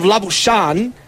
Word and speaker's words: Labuschagne: The Labuschagne is the Afrikaans pronunciation, but the origin of the surname is actually Flemish. – Mm. – So the pronunciation of Labuschan is actually the full pronunciation, the Flemish pronunciation, as Thanabala Sangam comes in, bowls the next delivery, bunted away by Solway.